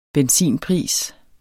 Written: benzinpris

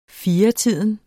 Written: firetiden

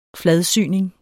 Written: fladsyning

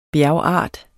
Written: bjergart

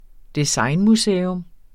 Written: designmuseum

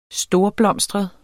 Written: storblomstret

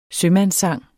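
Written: SØMANDSSANGE

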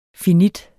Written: finit

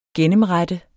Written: gennemrette

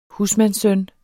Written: husmandssøn

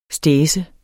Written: stæse

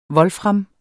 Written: wolfram

